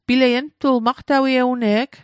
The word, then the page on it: ugtul: Mi'gmaq